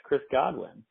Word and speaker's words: Godwin: And then you got Chris Goodwin, he's not like a name brand, but he's been going off. – He's definitely not a name brand, because he said his name wrong. It's Chris Godwin.